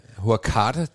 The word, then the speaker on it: Hurghada: Hurghada. – Hurghada udtalte de det faktisk. – Okay, også Hurghada, det lyder som sådan en spansk...